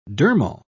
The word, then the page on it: dermal: British English